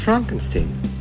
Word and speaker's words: Frankenstein: It's pronounced Frankenstein.